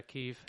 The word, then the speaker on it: Kyiv: goes to Kyiv? Is that how you pronounce it? – Yeah, Kyiv.